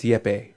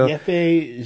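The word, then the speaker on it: Jeppe: Jamie pointed out that they are a gypsy brewer, even though the brewer's name is like J-E-P-P-E. I think it's Yeppe, I think is how you say his name.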